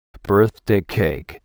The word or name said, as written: birthday cake